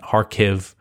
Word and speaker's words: Kharkiv: So some people pronounce Kharkiv, Kharkiv, or Kharkiv. I double checked, you know, the Ukrainian pronunciation, and Kharkiv seems to be it. You know, and then the Russian pronunciation might be different, but Kharkiv is what I saw most commonly.